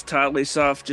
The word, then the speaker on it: TotleighSoft: I've always pronounced this Totally Soft, just because Totally. – The name came from like this stupid show my dad likes, Totally Towers.